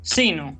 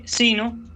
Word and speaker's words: sino: Vamos a recordar cómo se pronuncia la conjunción sino. Como escucharon, dije sino y no dije sino, es decir, que esta conjunción hay que pronunciarla como si fuera una palabra llana.